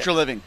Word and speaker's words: Treliving: Treliving.